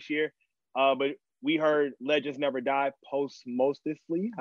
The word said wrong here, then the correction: posthumously: however you pronounce that. Um, and I really did like that album. Like Legends Never Die had a lot of good songs on it. – If you haven't heard it, posthumously, there we go.